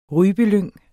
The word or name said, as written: rypelyng